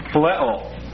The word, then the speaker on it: phileo: That's spelled P-H-I-L-E, long O, phileo.